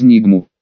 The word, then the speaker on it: Нигму: Только-только, если они не попытаются управлять мной, через Нигму. Хитро, с ударением на последний слог протянул мэр, все еще прозревая.